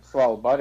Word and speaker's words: Svalbard: Svalbard, yeah.